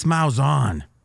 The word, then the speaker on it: Malzahn: This Malzahn.